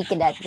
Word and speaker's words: Quidet: Yes, it's Quidet. Yeah, G. Quidet.